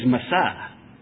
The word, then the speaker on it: masah: And the Old Testament verb for this special anointing is masah, and it's spelled M-A-S-A-H. M-A-S-A-H.